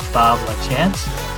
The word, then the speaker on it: Bob Lachance: And today we have a special guest here with us, Bob Lachance.